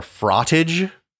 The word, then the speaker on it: frottage: It's called fraught or frottage. Rhymes with cottage.